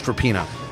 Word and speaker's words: Rapinoe: I want to get into Megan Rapinoe, and for the record, it is pronounced Rapinoe.